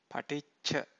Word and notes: paticca: Pali

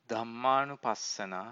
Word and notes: dhammanupassana: Pali

